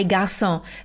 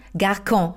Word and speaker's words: Garçon: Wenn unter dem C diese kleine halbe 5 ist, so mini klein, das CD, dann spricht man das C nicht wie ein K aus, sondern wirklich wie ein S bei Garçon. Wenn da kein CD drunter wäre, dann würde man Garcon sagen.